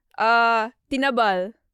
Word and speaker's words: tinabal: It's called tinabal. – Yeah, but you have to spell that. – Oh. T-I-N-A-B-A-L.